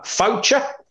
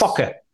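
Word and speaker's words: Faucher: And it is Calvin, how are we pronouncing that? Foucher? – Yes. – Focker? – No. – No, we're not good with Focker.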